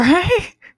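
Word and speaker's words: Schiach: Shack Birchten? That cannot be how that's pronounced. It's S-C-H-I-A-C-H Birchten.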